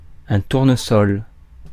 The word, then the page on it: tournesol: [tuʁ.nə.sɔl]; France